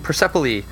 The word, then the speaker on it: Persepolis: So uh, next week we're going to be doing Persepolis.